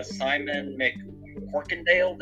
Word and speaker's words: Simon McCorkindale: Of course, Philip Fitzroyce, who played by Simon McCorkindale. Did I say that right? – Yes, I believe you did.